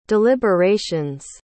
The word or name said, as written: deliberations